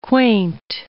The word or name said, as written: quaint